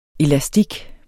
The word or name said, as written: elastik